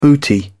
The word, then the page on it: booty: British English